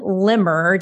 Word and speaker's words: Limmer: So I will go ahead and introduce Kirsten Limmer.